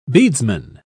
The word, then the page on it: bedesman: British English